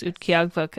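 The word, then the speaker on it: Utqiaġvik: Barlow should be called by its indigenous name, which is Utqiagvuk. Again, I will say that. And I'm really proud of myself because I looked it up to figure out how to pronounce it ahead of time.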